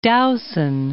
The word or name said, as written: Dowson